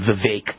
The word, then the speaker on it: Vivek: Vivek Ramaswamy, I think, or actually pronounce the name Vivek.